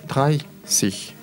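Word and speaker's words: dreißig: Дальше э, слово 30 тоже проблематичное. Там единственный случай, когда у вас суффикс не цих, а сих пишется через SZ. Поэтому try sich будет.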